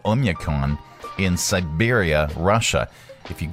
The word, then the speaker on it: Oymyakon: But how do you pronounce this? – Oymyakon. – Oymyakon. All right. That's fine. That's. We'll just go with that.